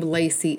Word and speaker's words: Lay-Cee: So I gotta tell y'all, it's one of the things that I really, really hate about having the name Lay-Cee